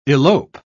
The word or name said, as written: elope